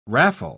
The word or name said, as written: raffle